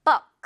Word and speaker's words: book: 역시 이것도 우리가 표기를 할 때는 그냥 북 이렇게 표기를 우로 하긴 하지만 원어민 발음 잘 듣다 보면 가끔은 북처럼 느껴질 때도 있을 거예요. Book 이렇게 발음을 하기 때문에 Book. book 너무나 북 아니라는 거북 아니고요 book 오케이 예문 볼까요 이 책은 정말 흥미로워 This book is very interesting This 북 아니죠 book This book is very interesting 우 발음을 짧게 자 마지막 단어입니다 이것도 요리하다 또는 뭐 요리사 다 이런 표현들이 있는데 발음 어떻게 한다?